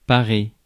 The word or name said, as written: adorn